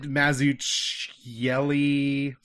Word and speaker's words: Mazzucchelli: It's David Mazzucchelli, something like that.